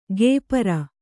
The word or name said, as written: ಕೆಪರ